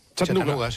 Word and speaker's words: Chattanooga: ¿Se pronuncia así en Chattanooga? – Chattanooga. Sí. Chattanooga.